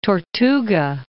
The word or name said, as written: Tortuga